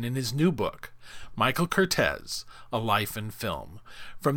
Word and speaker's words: Curtiz: And the first thing I learned was how to pronounce it correctly, Curtiz, which I'll try. – Yeah, it's Curtiz, and, you know, it's interesting...